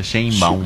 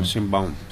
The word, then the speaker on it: Sheinbaum: Sheinbaum. – Sheinbaum, sí, la mexicana.